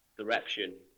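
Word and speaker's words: Surreption: Now, you rolled on from that album to, I can never pronounce this right, is it Sereptin? Sereptin? – Sereption. – Sereption. Okay.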